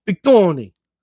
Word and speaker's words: Big Tony: Yes, and you have to say his name like Bigoni, like Bigoni. Like it's like one word, but it's two words.